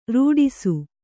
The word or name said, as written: ರಟಿಸು